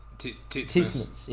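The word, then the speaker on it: Titmus: Like I can't pronounce her name properly. Sismus, Ariana there. – Oh, Tismus. – Yeah, that's it.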